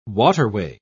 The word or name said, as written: waterway